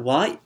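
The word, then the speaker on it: wa (は): And that wa, although it's said like, it's written like wa, the ha in hiragana, it's going to be pronounced wa.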